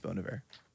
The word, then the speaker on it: Bon Iver: You are right because I watched two interviews and it is Bon Iver. – But I was going Bon Iver was how I pronounce it, but that's my accent.